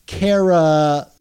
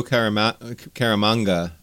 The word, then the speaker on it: Caramagna: and letters by VCs Joe Caramagna. You know, I'm trying to say that right because I'm actually mispronouncing it. It's like lasagna. – Oh, I always say Joe Caramanga, but you're probably right.